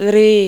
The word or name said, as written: ॡ